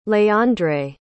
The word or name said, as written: leandre